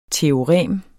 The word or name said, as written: TEOREMET